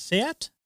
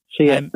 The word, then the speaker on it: Seat: Is it Seat? – Seat. That's how you say it.